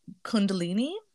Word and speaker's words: Kundalini: Sekhmet is strongly associated with Kundalini, I think mm-hmm.